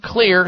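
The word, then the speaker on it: Xlear: Just remember, Clear, spelled with an X, X-L-E-A-R, nasal sprays, and a variety of them at finer drug and grocery stores and health food stores nationwide.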